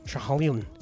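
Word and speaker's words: Schiehallion: Shehalion. I can't do it. – Shehalion. – That's better.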